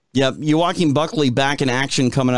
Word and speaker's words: Joaquin: Like I can't even say his name, Joe Quinn. – Quinn. Oh, uh Joaquin Buckley.